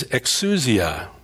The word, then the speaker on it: exousia: The Greek word here for the power that Jesus gives his newly minted apostles is exousia. I'll spell that for you. It's E-X-O-U-S-I-A. exousia.